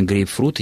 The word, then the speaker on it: грейпфрут: И, конечно, грейпфрут, и никаких ударений и вариаций здесь быть не может в принципе.